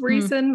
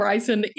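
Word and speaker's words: Rhysand: First of all, unless you had that pronunciation page, you had no idea how to even say his name. – But my girlfriend said that she knows that his name is Reason because she had the pronunciation. But in her mind, no self-respecting man can be Reese and be like, so she calls him Ryson anyway.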